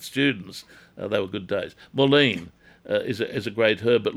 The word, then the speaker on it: mullein: M-U-L-L-E-I-N, mullein.